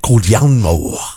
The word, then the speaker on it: Guglielmo: Guglielmo.